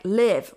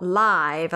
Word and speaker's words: live: Only she says it like live rhymes with give, as in the same house, not live, as in live from New York.